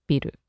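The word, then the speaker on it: biru (ビル): And of course, it's birudingu, but we never say birudingu, we say biru. Biru ga yureru. Yureru means to shake. – By the way, this shouldn't be mispronounced as biru ga yureru, which would mean my beer is shaking, which is also a possibility during an earthquake.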